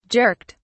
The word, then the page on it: jerked: British English